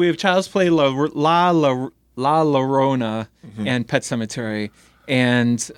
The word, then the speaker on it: La Llorona: How do you say it? Llorona? – Yeah. – La Llorona.